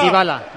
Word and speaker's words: Dybala: Dybala, Dybala.